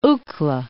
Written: Uccle

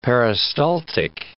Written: peristaltic

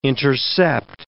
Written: intercept

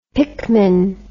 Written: pickman

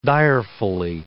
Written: direfully

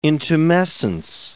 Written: intumescence

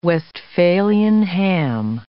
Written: Westphalian ham

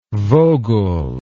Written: vogul

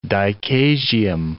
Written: dichasium

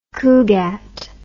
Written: cugat

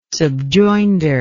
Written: subjoinder

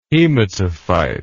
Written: hematophyte

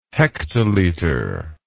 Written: hektoliter

